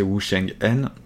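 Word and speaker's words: Wu Cheng'en: Euh, Shiyuji, donc c'est l'auteur, c'est Wu euh, ça s'écrit euh, euh, de manière bête, on peut le prononcer Wu Sheng En.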